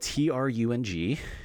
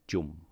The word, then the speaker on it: Trung: It's spelled TRUNG, and people pronounce it T-R-U-N. – as Trung, which is what I went by for a brief period growing up, but it's actually pronounced Jum.